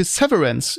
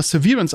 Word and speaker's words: Severance: Und ja, ich habe den einen oder anderen Geheimtipp geguckt, zum Beispiel Severance. Ich hätte es immer Severance ausgesprochen, aber es wird scheinbar Severance ausgesprochen.